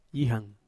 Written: ihang

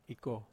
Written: ko

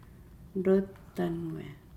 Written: lotanuaa

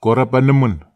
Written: korapanemen

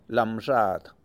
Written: lamXaad

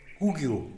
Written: kugyu

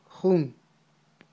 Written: jun

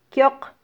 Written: kyoq

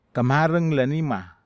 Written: kamareg la nima

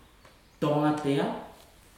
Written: toga tea

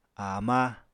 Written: ama

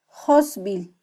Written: josbil

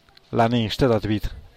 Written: la ni xtedabid

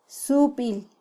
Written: subil

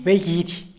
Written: begidy

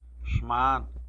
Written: xman

